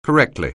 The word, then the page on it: correctly: anglais